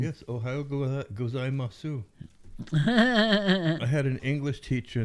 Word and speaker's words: gozaimasu: In Japan, in Tokyo anyway, they don't pronounce the U. – Oh. – So, gozaimasu. It's kind of, that's a Tokyo accent. They slur a lot of the vowels, like in S-H-I, it's they don't pronounce the I.